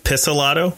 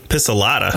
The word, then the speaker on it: Pizzolatto: Nick Pizzolatto. I'm definitely mispronouncing that last name. – I think it's Piss. Pizzolatto. – Pizzolatto? – Yeah. Pizzolatto.